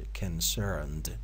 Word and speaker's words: concerned: As far as I'm concerned، این صدای او در این کلمه کسر تلفظ میشه concerned،